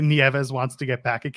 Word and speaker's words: Nieves: moving on, Tiffany Nieves, which, by the way, every time I see her name, I want to pronounce it Neves. So thankfully it's Nieves.